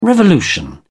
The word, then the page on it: revolution: British English